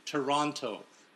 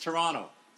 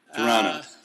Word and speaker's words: Toronto: You and a bunch of American friends of mine pronounce that second T, Toronto. That's not how it flies here north of the border. It's Toronto. – Okay. – A silent T.